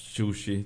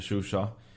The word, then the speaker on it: Shusha: Azerbaijan hävdar ju att delar av den här regionen, bland annat då den här Shushi, tror jag den kallas, eller Shusha. Jag tror att det finns olika uttal beroende på vilken sida man frågar.